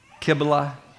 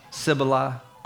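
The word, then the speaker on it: Cybele: I've read and heard and looked at different ways of pronouncing her name. Kibla, Sibila, Kubla. There's like 5, 6, 7 different ways to pronounce her name.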